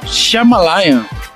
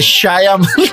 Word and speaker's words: Shyamalan: Não, o acento é no A. – Eu falo de novo. – É Shyamalan.